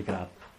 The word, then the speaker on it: Krapp: La Crap si scrive K-R-A-P-P, però suona come crap, che in inglese vuol dire immondizia.